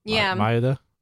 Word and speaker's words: Maeda: We're actually going to be covering a news piece about this guy, the Jun Maeda.